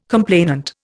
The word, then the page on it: complainant: Indian